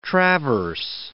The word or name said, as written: traverse